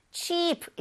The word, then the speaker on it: cheap: 여기에서 chip 이렇게 너무 짧게 발음을 하게 되면 우리 감자칩 얘기할 때그 칩이 되니까 cheap 이렇게 길게 발음을 해주세요.